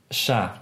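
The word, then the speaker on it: chatte: Sha, but you don't pronounce the T. If it's C-H-A-T-T-E,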